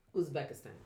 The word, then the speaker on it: Uzbekistan: Uzbekistan.